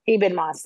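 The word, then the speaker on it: Ebon Moss: The end of that bear episode forks with, I can't pronounce his name, but not Eben Jeremy Moss. Allen White character. Eben Moss. Thank you.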